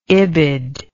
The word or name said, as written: ibid